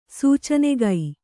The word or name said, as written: ಸೂಚನೆಗೈ